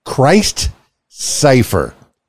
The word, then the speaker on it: Christ Cipher: Christ Cipher. Christ, spelled like Jesus Christ, C-H-R-I-S-T, Cipher, C-Y-P-H-E-R.